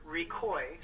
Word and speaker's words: Ricoy: My name is Peter Recoy, R-I-C-O-Y, and I'm an attorney with Schwabe, Williamson & Wyatt, and I'm representing Oregon's Health Co-op.